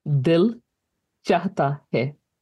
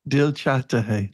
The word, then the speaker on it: Dil Chahta Hai: And how do you say the name of this piece again? – Dil Chahta Hai. I don't make it. – Dil Chahta Hai. – You got it.